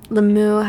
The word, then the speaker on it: Limoux: Limoux, how do you spell that? – L-I-M-O-U-X.